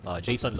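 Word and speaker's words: Jayson: I'm on Facebook and Instagram, Jason Lo. My name is spelled J-A-Y-S-O-N, L-O.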